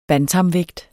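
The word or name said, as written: BANTAMVÆGT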